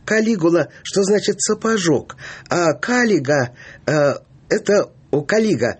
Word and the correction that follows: калига: простите, оговорился, у римлян не надо говорить на первый слог ударение. Калига это обувь легионера.